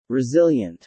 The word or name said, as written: resilient